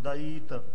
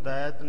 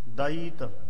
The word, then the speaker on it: दैत: ते इस दा जिहड़ा उच्चारण है ओ ध्यान सहित करना दईत दद्दा ईड़ी नूं बिहारी ए तत्ता मुक्ता इनू दैत नहीं उच्चारण करना दईत दईत रचे दुष्ट दुखदाई